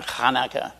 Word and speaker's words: Hanukkah: Do you know when Hanukkah? That's the way it's pronounced. It's a chet sound in the front there.